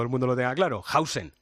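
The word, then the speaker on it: Huijsen: Dean Hausen, Hausen, no sé cómo prefieres que te llamemos. – Hausen, Hausen, Hausen, Hausen, Hausen, Hausen.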